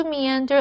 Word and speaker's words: meander: 雏菊的植物学名叫做什么呢？最后我们看的是 meander 这个动词，注意它的发音 ,me 是一个音节 ,ander 是另外一个音节，所以念成 meander。meander 是指漫步，它在这里是不及物动词，所以后面要加介系词才能加受词。例如 ,locals and visitors love to meander along the beautiful coastline.